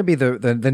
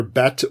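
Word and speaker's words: Bette: Her name is either Bet or Betty, depending on how she's pronouncing that. There is no I. It's B-E-T-T-E.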